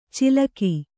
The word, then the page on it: ಚಿಲಕಿ: cilaki